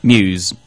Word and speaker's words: Muse: Pronounce the name of the band properly. – Muse. – Perdí la apuesta. – You lost the bet. – Oh, okay. How'd you say? – Moose for me.